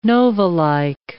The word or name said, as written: novalike